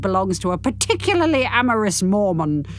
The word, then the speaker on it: Mormon: You said it right the first time, dear. – Because it's M-O-N at the end, isn't it? – Yes. – Not M-A-N, like a superhero. – No. – More man.